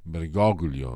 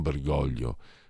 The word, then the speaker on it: Bergoglio: Perché quando ho visto Milei abbracciare con enfasi estatica eh, Bergoglio, si dice Bergoglio, non Bergoglio, perché lui è argentino, quindi bisogna pronunciarlo in castigliano,